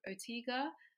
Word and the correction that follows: Ortega: Okay, Ortega, yeah. – Ortega, sorry.